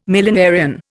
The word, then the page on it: millenarian: Indian